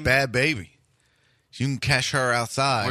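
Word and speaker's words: Bhad Bhabie: So I used to think it was called Bad Bobby because it was B-A-H-B-I. But then someone corrected me and said, no, she pronounced it baby.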